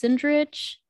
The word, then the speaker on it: Sindrich: Like, I mean, people don't know how to pronounce my name, even though it's like phonetically, when you read it phonetically, that's the correct way to read it. – Sindrich, right? – It's Sindrich. – Sindrich. – Yeah, C-H.